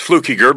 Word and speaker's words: Fluckiger: There's no N in it. It's Fluckiger, but that's fine.